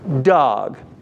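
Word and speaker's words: dag: Now, to be clear here, it's spelled with the letter A, D-A-G, but it's pronounced dog.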